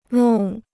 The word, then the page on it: 弄: lòng